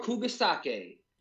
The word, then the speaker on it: Kugisaki: Kugisaki. – Nobara Kugisaki.